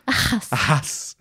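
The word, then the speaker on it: Ehasz: There's The Dragon Prince, which is show run by Aaron, how do you say his last name again? Ahas? – Ahas.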